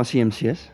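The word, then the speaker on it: CMC$: Of tenminste, hoe spreek je artiesten nou uit? Het staat voor Chemicalic Stacks, maar hoe zeg jij het? – Ja, gewoon CMCS.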